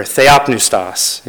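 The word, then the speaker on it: theopneustos (θεόπνευστος): But inspired by God is theopneustos, or theopneustos, if you want to pronounce it correctly.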